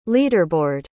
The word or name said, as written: leaderboard